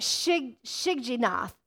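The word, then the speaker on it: Shigionoth: It's so small, but it says this, Habakkuk 3 verse 1, a prayer of Habakkuk the prophet on, let me say this right, Shigginath. Everybody say Shigginath.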